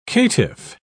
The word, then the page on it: caitiff: British English